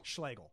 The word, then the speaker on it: Schlegel: Rhymes with bagel. – Is it Schlegel or Schlegel? – Schlegel.